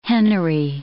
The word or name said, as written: hennery